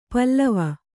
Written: ಪೇಲವ

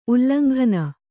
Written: ಉಲ್ಲಂಘನೆ

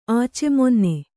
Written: ಆಚೆಮೊನ್ನೆ

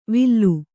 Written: ವಿಲ್ಲು

